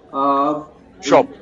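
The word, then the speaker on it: Schopf: I know you hooked up with Bodo, I hope I don't butcher his name, Schoff? – Schoff. Bodo Schoff.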